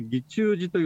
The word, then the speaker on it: 義仲寺: 現在実はその辺りに義中寺義仲寺と書いて義中寺